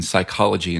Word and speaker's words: psychology: To give a really simple example, the reason you don't pronounce the p sound in psychology and pterodactyl, we don't say psychology and pterodactyl, is because English native words don't allow p plus an s or p plus a t at the beginning of a word.